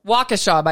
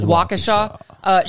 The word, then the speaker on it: Waukesha: So Waukesha, by the way. That's how you say it? Waukesha. Shame on any journalist who didn't look that up.